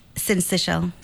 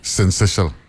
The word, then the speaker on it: syncytial: No, I, I trying to, how, how you pronounce the second word? – Syncytial. – Syncytial. – Yeah, okay. it's spelled S Y N C Y T.